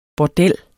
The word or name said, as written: bordel